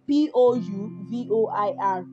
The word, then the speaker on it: pouvoir: Pouvoir is in its infinitive form, right? Because yes, that's not be conjugated and it is spelled P O U V O I R.